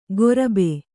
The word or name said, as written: ಗೊರಬೆ